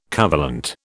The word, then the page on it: covalent: British English